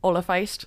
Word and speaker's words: Oilliphéist: Oilliphéist. – Oilliphéist? – Yep. Like feisty.